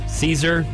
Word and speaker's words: Caesar: Caesar rhymes with Weezer, either salad or, you know, him himself.